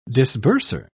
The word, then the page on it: disburser: British English